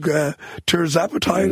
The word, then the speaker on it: tirzepatide: Tirzepatide, is that how it's pronounced? Yeah.